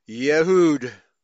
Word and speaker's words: Yehud: era with falcon or eagle and Aramaic inscription Yehud, pronounced like a Y.